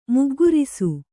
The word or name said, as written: ಮೊಗ್ಗರಿಸು